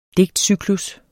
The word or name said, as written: digtcyklus